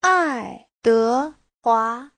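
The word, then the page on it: 爱德华: ai4de2hua2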